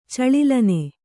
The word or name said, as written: ಚಳಿಲ್ಲನೆ